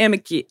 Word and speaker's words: amici: The three amici.